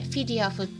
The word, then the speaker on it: FEDIAF: でそれで計算してみるとフィディアフって呼ばれる FEDIAF って書いてフィディアフって読むアーフコみたいなねアーフコは AAFI なんだっけ AAFCO ですね。